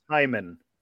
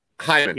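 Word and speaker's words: Hamon: It's pronounced Haman. – Haman.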